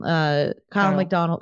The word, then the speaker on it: Kyle McDonald: So what would be more likely is like someone like, I don't know who, Keel McDonald or something. Kyle McDonald. I know I just say it wrong.